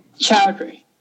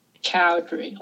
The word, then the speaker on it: Choudhury: Choudhury. Choudhury. Like Choudhury. Chow. – Okay. – And then Dree.